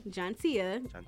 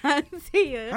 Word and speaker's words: Jontia: Not Janita. Jontia. Not Jontavia. Jontia.